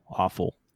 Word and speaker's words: offal: So the innards, in another word, and it's spelled OFFAL, awful. So, and ironically, sounds the same as the other awful, AWFUL.